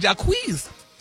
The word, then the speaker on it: Jacquees: How you say his name? Jacquees. – Yeah.